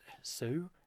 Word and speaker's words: Hsu: It's like, man, Jerry, like, Sue? How'd you say it? Jerry Hsu? It's a silent H, yeah, isn't it?